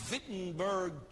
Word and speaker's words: Wittenberg: And he walked to the Wittenberg, pronounced Wittenberg Church, and nailed those 95 theses on the church door, beginning the Reformation period of that century.